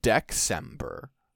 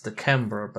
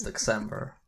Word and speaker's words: Dexember: Thank you for pronouncing it properly. A lot of people were like, it's Dekember, but no, I invented the word. So I say how it goes, it's Dexember, and you can't stop me.